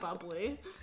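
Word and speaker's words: Bubly: This podcast is brought to you, as always, by Boobly. Grapefruit sparkling water beverage. No calories, no sweeteners, all smiles. Boobly sparkling water beverage. Thank you. – It's pronounced bubbly. – Well, then why did they spell it like that? – I don't know.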